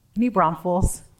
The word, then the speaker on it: New Braunfels: Talk to me about New Braunfels or New Braunfels, or how do you say that? – New Braunfels.